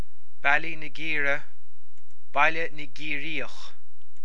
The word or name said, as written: Baile na gCiarraíoch